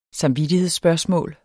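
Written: samvittighedsspørgsmål

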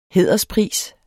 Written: hæderspris

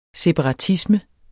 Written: separatisme

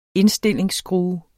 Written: Indstilling

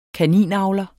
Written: kaninavler

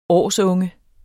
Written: årsunge

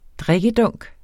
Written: drikkedunk

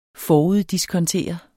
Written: FORUDDISKONTERET

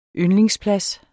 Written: yndlingsplads

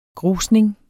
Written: grusning

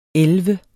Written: elleve

